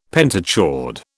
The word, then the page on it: pentachord: British English